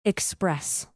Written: express

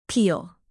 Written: peal

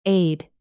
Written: aid

